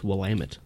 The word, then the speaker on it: Willamette: No, that's Willamette, apparently. – Willamette. Depending on which homebrew shop you go to, it's a different pronunciation every time.